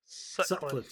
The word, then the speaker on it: Sutcliff: We learn that this guy's name is Marcus Scutliff. Did I say that right? – Sutcliff, thank you.